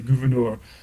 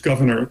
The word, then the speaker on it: Gouverneur: People did not pronounce it in the French way, Gouverneur. But one of Morris's ancestors suggests it was just governor, the way I've been saying it.